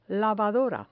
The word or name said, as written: lavadora